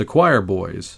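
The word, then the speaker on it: Quireboys: Over there, they're just the Choir Boys. And it's spelled Q-U-I-R-E Boys.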